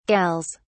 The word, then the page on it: gals: British English